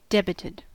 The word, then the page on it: debited: American English